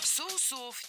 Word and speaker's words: соусов: На самом деле ягоды, помимо того, что используют их в мариновании мяса, приготовлении соусов и лимонадов, естественно, десертов. – Соусов, неверно. Правильный вариант – соусов. Это слово с неподвижным ударением на основе. Поэтому во всех формах ударяем первый слог.